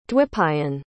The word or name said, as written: Dwipayan